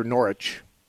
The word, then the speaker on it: Norwich: Now, do you say Norwich or Norwich? – Well, the second pronunciation, neighbor Steve, is English. We say Norwich here in Connecticut.